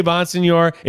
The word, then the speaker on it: Bonsignore: So here's the thing. I say Bonsignor. It is Bonsignore. – Oh, so we're doing it right. – That's how you would say it. But I say Bonsignor because, like, people don't have time.